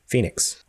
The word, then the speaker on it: Fenyx: including a better look at Immortals Fenyx. Phoenix. Immortal, it's spelled weird. Why is it spelled like that?